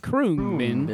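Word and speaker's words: Khruangbin: Krung Bin.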